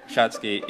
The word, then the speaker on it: Shatskiy: Shatskiy. S H A T S K I Y.